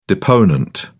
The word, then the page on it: deponent: British English